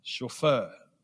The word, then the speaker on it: chauffeur: You know, this man used to be a driver, you know, a chauffeur. Chauffeur. That's the right pronunciation. So it's a French word, chauffeur.